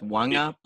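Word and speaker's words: Waqa: Um, Parramatta, Waka Blake, indefinite. That's a loss. I believe it's they pronounced Wanga Blake.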